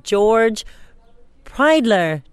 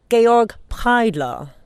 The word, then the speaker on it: Georg Pridler: It's George Pridler de Charme, but I've been learning German, so for all of you, Daniel Freib included, he'll probably try to get the pronunciation right. It's actually pronounced Georg Pridler. So it's Georg Pridler is today's Pridler de Charme.